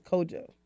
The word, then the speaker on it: Kwadwo: It's spelled Kwadwo, but I think he says Kojo.